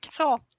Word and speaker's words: Saul: Saul. – Like King Saul from the Bible, you know, Saul.